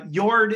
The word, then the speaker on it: Jord: So Jord is pronounced Jord in Norwegian, but maybe like Jord or Ford from the standpoint of U.S. Depending on where you'd be in the U.S., you might pronounce it Jord or Jord. – J-O-R-D, right? – But, yeah.